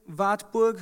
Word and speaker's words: Wartburg: So she moved to Wartburg, I'm just going to pronounce it in German, castle.